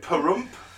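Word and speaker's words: Pahrump: The Trump of Perump, I believe that's pronounced, brothel owner Dennis Hoff, 72, surged to victory in his election in the 36th district of rural Nevada.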